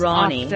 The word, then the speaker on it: Rani: But Ronnie is spelt R-A-N-I. It is pronounced Ronnie though, because it is Ronnie.